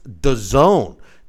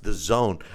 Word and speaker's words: DAZN: Anthony Joshua, we all knew he is fighting Alexander Povetkin in September on, and it's pronounced The Zone, DAZN. It's pronounced The Zone.